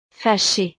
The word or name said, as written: fachee